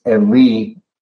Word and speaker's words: Eli: It says, about the ninth hour, Jesus cried out with a loud voice, saying, we usually pronounce it Eli, Eli, lema sabachthani, but I believe it's Eli, Eli. El is the Hebrew word for God.